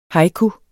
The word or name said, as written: haiku